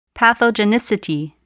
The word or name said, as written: pathogenicity